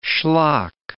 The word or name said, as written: schlock